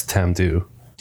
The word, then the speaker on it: Tamdhu: It's Tamdhu. – Tamdhu, yeah. – I'm pronouncing that one right. – That one you got.